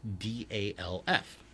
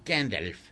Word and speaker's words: Gandalf: He said Gandalf. And it's like, I couldn't do it. And he said it rhymes with half, as in half a sixpence. – Oh, Gandalf.